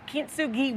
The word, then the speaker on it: Kintsugi: So Candace is a travel and culture writer and the author of Kintsugi Wellness.